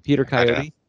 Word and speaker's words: Coyote: Is it Peter Coyote? – I thought it was Coyote, but Coyote. Peter Coyote. – That's the way I've always said it.